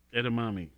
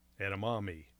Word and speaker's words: edamame: Edamame. – Yes. E-D-A-M, Edam, and then A-M-E, Edamame.